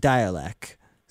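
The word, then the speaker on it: Dälek: Well, you might think that they're called Dalek because, well, if you look at their name, it's spelled mostly the same way, except for an umlaut over the A, but, well, they maintain it's pronounced Dialek.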